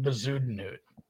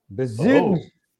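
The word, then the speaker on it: Bezuidenhout: I don't know that I can pronounce it right, but I do think you're short a syllable. I think it's four syllables. I think it's like Bezudenhut. – Bezudenhut.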